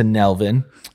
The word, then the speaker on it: Nelvin: But I got introduced to Nelvin. Melvin with an N. That's how he said I was supposed to pronounce his name.